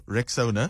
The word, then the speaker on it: Rexona: So first things first, it's Rexona, like Rexona, so Rexona. So say it right, say it proud.